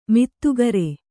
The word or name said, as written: ಮಾಧುಕರಿ